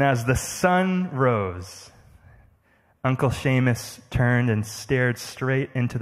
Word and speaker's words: Seamus: where he would celebrate his beloved Uncle Seamus and his 80th birthday.